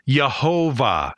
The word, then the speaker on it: Yehovah: The debate has to do with the pronunciation of something in Hebrew called a cholam. It's the letter Vav with a dot over it, and it's pronounced like an O. So if you pronounce the cholam, then it's Yehovah, which sounds a lot like the English name Jehovah.